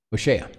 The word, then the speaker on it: Hoshea: Hoshea.